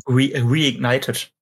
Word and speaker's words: Reignited: Spyro ich kann es gar nicht aussprechen. Reignet? Oder wie? – Trilogy? Reignited. – Reignited, so.